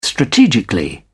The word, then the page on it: strategically: British English